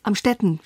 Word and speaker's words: Amstetten: Amstetten, spricht man.